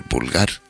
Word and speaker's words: Bulgar: En primer lugar vamos a escuchar la introducción al tema, Aguir Bulgar, Bulgar con V de corta de Valparaíso, sino con V, Bulgar de Bulgaria. Aguir Bulgar, lo pronunciamos así porque si digo vulgar, a lo mejor pues ahí donde está el posible error.